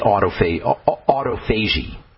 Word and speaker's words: autophagy: It's one of the best ways to stimulate autophagy. That's how you say it.